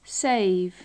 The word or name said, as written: save